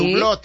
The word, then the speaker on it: Hublot: ¿Cómo se pronuncia esa marca? – Hublot.